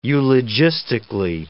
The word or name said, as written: eulogistically